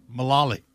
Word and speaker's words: Mullally: Mullally. – It sounds like you're saying it wrong, but you are saying it right.